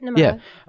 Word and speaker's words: Namara: Namara? Is that how you say it? – That's right, Namara.